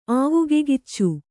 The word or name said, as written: ಆವಗೆಗಿಚ್ಚು